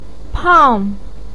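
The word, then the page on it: pang4: tone 4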